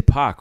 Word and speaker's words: Paak: I would say .Paak, right? Because it's P-A-A-K. – I've been saying .Paak.